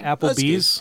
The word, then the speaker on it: Applebee's: Applebee's. Am I saying it right? Applebee's? – Applebee's.